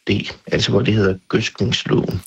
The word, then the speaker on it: gødskningsloven: Jamen, hvad er så den korrekte udtale af ordet gødskningsloven? Gødskningsloven. Ja, det har vi spurgt sprogforsker Michael Ejstrup om. – Hvad hedder det? Gødskningsloven eller gødskningsloven? Jeg vil sige, at der er lidt frit valg,